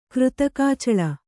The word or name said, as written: ಕೃತಕಾಚಳ